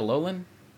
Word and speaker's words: Alolan: Alolan? – Yeah.